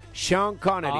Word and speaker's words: Sean Connery: Es Sean, se escribe, pero se pronuncia Sean Connery.